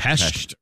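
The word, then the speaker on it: Pest: So I went to Budapest, which, by the way, when you pronounce it Budapest in Budapest, they tell you it's Budapest. – I was going to say it's Pesht, right? – Pesht. Yeah. Pesht.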